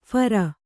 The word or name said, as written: ಫೇರಿ